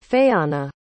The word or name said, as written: fayanna